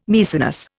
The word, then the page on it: Maecenas: Indian